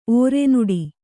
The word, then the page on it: ಓರೆನುಡಿ: ōrenuḍi